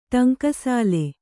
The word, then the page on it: ಠಂಕಸಾಲೆ: ṭhaŋkasāle